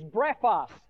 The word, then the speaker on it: brephos: The word for babe in the Greek language is brephos. Brephos, B-R-E-P-H-O-S is the way we'd spell it in English.